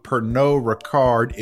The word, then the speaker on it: Pernod Ricard: Jackson, when you say Pernod Ricard, I mean, it's not Pernod Ricard. So, right? – I just pronounce every letter that's in there, Pernod. – I think with French, you leave the last letter off for like every other word. Is that the rule? – I think sometimes it's three letters. E-A-U-X?